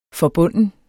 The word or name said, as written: forbunden